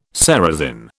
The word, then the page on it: ceresin: British English